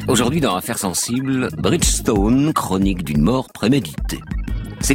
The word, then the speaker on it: Bridgestone: Le 16 septembre dernier, Bridgestone, que nous prononcerons Bridgestone, comme le font les salariés de cette entreprise,